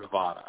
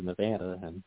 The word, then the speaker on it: Nevada: The whole Nevada and Nevada, well, the way that locals say it here is Nevada, And I noticed that people from, east of New Mexico, they tend to say Nevada.